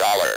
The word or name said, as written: dollar